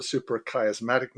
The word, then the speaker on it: suprachiasmatic: There's a master clock in the brain called the suprachiasmatic nucleus, just to get the pronunciation right.